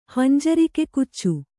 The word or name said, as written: ಹಂಜರಿಕೆಕುಚ್ಚು